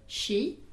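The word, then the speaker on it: ŝi: Comment dit-on elle ? – She. – Et ça s'écrit S chapeau I.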